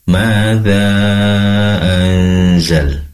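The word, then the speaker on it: ماذا أنزل: Izin kami sampaikan terkait koreksinya ketika membaca madza anzal. Di sini ada nun sukun bertemu dengan zai, tentunya ikhfa. Ada dengung di sana. Tadi kurang lama durasi dengungnya ya.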